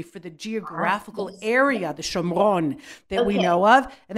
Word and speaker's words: Shomron: He sells it to Omri, and they say that Omri named the city Shomron, that's the Hebrew pronunciation, in Shomron, after the man Shemer that he'd bought the hill from.